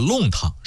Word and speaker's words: 弄: 在古代典籍当中，弄指的是小巷中套着的小巷，也就是弄巧成拙的那个弄。当然了，我在节目里边经常读成是弄，谁谁谁想弄死谁那个弄。话说在江南地区啊，这个弄堂是一个非常常见的说法，但是跟北京胡同它同样著名，具有鲜明地域特色。